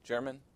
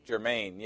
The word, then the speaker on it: Germain: How do you pronounce that? German? Germain. Yeah, that's it.